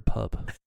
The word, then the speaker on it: PUP: Should I call them Poop the whole time or Pup? Uh, I think we should go with Pup. Okay. Love. – I'm pup.